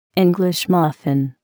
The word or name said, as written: English muffin